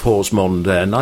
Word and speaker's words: Horsmonden: I popped in very briefly on the bank holiday weekend to catch about five overs of your second team playing against Horsmonden. Mm-hmm. And had I not spoken to a native of Horsmonden, I don't think I'd have pronounced the village that way.